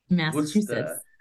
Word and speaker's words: Worcester: I'm in Worcester, Worcester. – Now, is that spelled like the sauce? Like the Worcestershire? – Great question. – The first part without the Shire. – Exactly. Yeah.